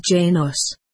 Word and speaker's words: Janos: He said, "I am a top explorer pilot, I am from Janos," and again he spelled it out for her, "Janos," and taught her to pronounce it as Janos, the first syllable stressed.